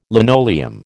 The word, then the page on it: linoleum: American English